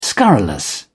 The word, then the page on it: scurrilous: British English